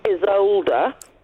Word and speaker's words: Isolde: So I have been listening to Richard Wagner's Tristan und Isolde.